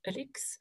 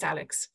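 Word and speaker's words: Alix: Alex is how she wants pronounced, like kind of French, Alex. I'm not doing that, it's Alex.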